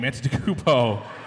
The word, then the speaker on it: Antetokounmpo: I just want to say that on Nick's computer, highlighted, is Giannis' last name, Antetokounmpo. Oh! – You did it. You fucking blew it. It's fine. I was going to challenge you to pronounce Giannis' last name... But you did it well, so it's fucking nothing.